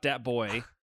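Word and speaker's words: Dat Boi: You're talking about Dat Boy. – Show some respect. – Oh, shit. What yeah. up? Oh, I don't know if you mentioned Dat Boy is spelled B-O-I. – Yeah, boy, B-O-I.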